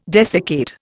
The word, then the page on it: desiccate: Indian